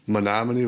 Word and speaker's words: Menominee: Menominee, Wisconsin. How did I do on that one? I got the name just about right on Menominee? – Menominee was pretty spot on.